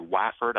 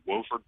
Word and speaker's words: Wofford: It's actually pronounced Wofford, W-O-F-F-O-R-D. I've heard Wafford, I've heard Wofford, I've heard Wofford, but it's Wofford, I assure you.